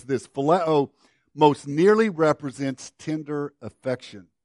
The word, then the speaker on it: phileo: A second Greek word for love, and it's the Greek word phileo. I'll spell that one for you, P-H-I-L-E-O, or you can spell it however you want. Just get the pronunciation, phileo.